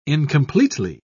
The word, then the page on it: incompletely: British English